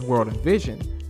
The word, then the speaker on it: WRLDINVSN: The unique logo of Clark and Marlon Watts' original brand, World in Vision, and spelled WRLDINVSN, proudly incorporated on each article. Pronounced as World in Vision, World in Vision's initial inspiration and hope of production was to help support Clark and Watts' families.